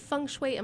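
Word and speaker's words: Feng Shui (風水): And again, is it Feng Shui? Am I saying that correctly? – Depends on what country you're from as to how it's pronounced, and that's perfect.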